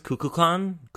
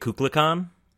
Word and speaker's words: Kukulkan: Yeah, the name was Kukulkan, but he kept pronouncing it Kuklikan.